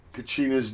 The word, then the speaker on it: cochina: We started off there with Cochinas doing Omaha. Oh yeah, I have bad pronunciation. It's cochina, okay? It's Espanol for kind of a pig, a dirty person.